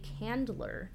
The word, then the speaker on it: Candler: Nope, that's not how you say his name. Senator Milton A. Candler. – Candler. Not Chandler from Friends. No, I really wanted to be Chandler, but there's no H.